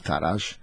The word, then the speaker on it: tarax: Il-verb tarax, importanti kif naqrawha tarax, għax l-aċċent li tkun fuq l-ewwel sillaba x tiġi fuq it-tieni sillaba tiġi tarax.